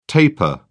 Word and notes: tapir: British English

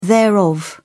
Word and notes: thereof: British English

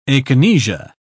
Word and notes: akinesia: British English

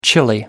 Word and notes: chilly: American English

